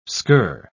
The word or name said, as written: skirr